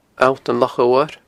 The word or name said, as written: Allt an Loch Odhair